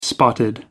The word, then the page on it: spotted: American English